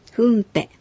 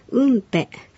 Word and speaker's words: フンペ: クジラのことをアイヌ語では一般的にフンペ、フンベと言いますが、ウンペ、ウンベと発音することもあるようです。